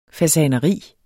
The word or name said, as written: FASANERIER